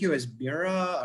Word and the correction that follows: Myra: or I hope I'm pronouncing her name right. The trainer. – Oh, Myra, yeah.